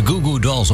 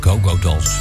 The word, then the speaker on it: Goo Goo Dolls: De Goo Goo Dolls of de Go Go Dolls. Het is maar hoe je de klemtoon ligt.